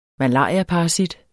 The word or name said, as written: malariaparasit